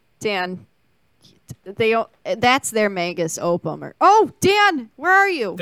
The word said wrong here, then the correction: magnum opus: Magnum Opus? – Oh, my God.